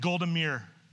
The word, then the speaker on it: Golda Meir: And Golda, you're saying it wrong. It's Golda Meir.